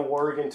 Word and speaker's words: warg: and then he's going to somehow warg. Am I saying that right? – Warg, yeah.